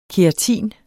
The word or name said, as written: keratin